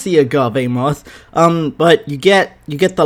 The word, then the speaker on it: agave: How do you pronounce agave? Alright, ready everyone? This is how we do it. – Agave. – Ah, I was right! – Agave. – Agave, yes.